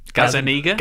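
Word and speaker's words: Gazzaniga: Is it Gazinga? – Gazaniga? – Gazaniga? There we go.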